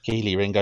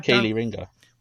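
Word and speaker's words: Kelee Ringo: It's the cornerback, Kelly Ringo. – Keely Ringo, you're right the first time. – Keely should be double E, surely. – It's Keely Ringo. – Well, that's wrong. It's spelt Kelly.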